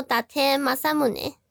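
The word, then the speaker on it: 伊達政宗: 它就念作打贴马萨姆内。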